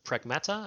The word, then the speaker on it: Pragmata: So it was a, how do you pronounce, Pragmata, I guess, is what you pronounce it, right? – Yes.